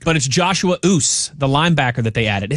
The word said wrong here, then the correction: Uche: Uche. – Oh, Uche. – Is it Uche? – Uche, yeah, okay.